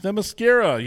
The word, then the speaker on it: Themyscira: Themyscira, yes, of course.